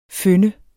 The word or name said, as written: føn